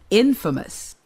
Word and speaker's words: infamous: 발음을 조심해야 되겠어요. 왜냐면요. famous는 알잖아요. 유명한. 그런데 인자가 있으니까 infamous가 되지 않고 발음이 infamous. – infamous가 돼요. 왜냐면 인의 강세를 치세요. 인의 강세가 있고 나중에 뒤에 있는 것 이름절에 인 빼놓고는 나머진 다 약한 음이기 때문에 이렇게 읽어요.